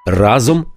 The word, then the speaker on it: разом: Пригадуєте помаранчеву революцію, Євробачення та гринджоли і їхню пісню Разом нас багато. Разом нас багато! разом наголос на перший склад.